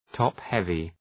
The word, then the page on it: top heavy: ‘tɒp,hevı